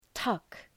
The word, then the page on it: tuck: tʌk